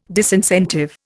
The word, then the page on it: disincentive: Indian